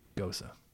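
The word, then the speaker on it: Gose: Gosa. – uh, Gosa, you've heard of Gosa. It's German. It's our beer pronounced G O hyphen Z U H like the thing that wrecked New York in Ghostbusters.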